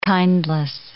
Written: kindless

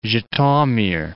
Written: Zhytomyr

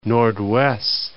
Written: Noordwes

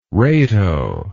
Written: rato

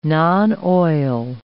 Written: non-oil

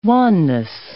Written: wanness

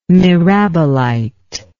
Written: mirabilite

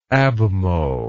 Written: abmho

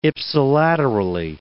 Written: ipsilaterally

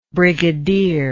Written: brigadiership

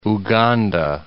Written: Uganda

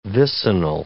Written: vicinal

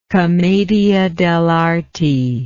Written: commedia dell'arte

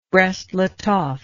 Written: brest litovsk

